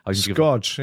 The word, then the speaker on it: Scorch: Wie spricht man das eigentlich aus? – Scorch, ja, Scorch. Genau, richtig.